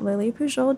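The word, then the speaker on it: Lili Pujol: Um, my handle is at Lily Pujol Jr. because I share my mother's name, and she had both of these things before me.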